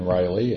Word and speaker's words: Reilly: Kevin Riley, and the last name spelled R-E-I-L-L-Y.